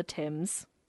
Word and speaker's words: Thames: It says, "As the Adventure Galley sailed down the Thames..."